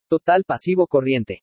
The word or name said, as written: TOTAL PASIVO CORRIENTE